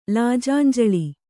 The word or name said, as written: ಲಾಜಾಂಜಲಿ